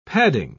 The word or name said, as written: padding